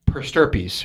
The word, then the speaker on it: per stirpes: And that is, am I saying it right? Per stirpes. Yeah, you said it Finally, right. like the 15th yeah, yeah.